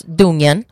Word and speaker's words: Dungen: It's spelled, I guess, kind of like dungeon, but it's, you know, they're Swedish, so it's Dungen.